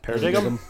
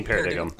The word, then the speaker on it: paradigm: Even if it is straight up English, why is there a G in the word paradigm? – Because it's silent. – Paradigm? I think you mean paradigm.